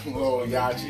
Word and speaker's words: Lil Yachty: It's none of that Little Yachty and all those other Lil Yachty, you even say it correctly.